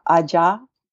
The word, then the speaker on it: Aja: And in Yoruba, Aja has three different definitions depending on how you pr- pronounce it. So it's AJA, three letters, Aja. It can mean dog, it can mean open, or it can mean witches.